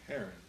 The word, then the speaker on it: Heron: Heron. – Heron, thank you. – Like the bird.